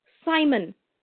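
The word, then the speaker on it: Psimon: I said, I know you don't pronounce P, but if you don't do something, then say Simon. And I'm like, that's stupid. And that's Simon. Whatever.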